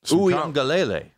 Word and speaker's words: Uiagalelei: You're trying to get uh, DJ Uiagalelei.